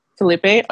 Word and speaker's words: Phillippe: They looked long and hard for the next actor to portray Anakin Skywalker, mostly unknowns, but names like Jonathan Brandis, I think it's Ryan Phillippe, is that how you say it? – Felipe.